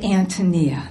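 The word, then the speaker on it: Ántonia: even though there is clearly an accent on the first syllable of the word Antonia in every edition of the book ever published.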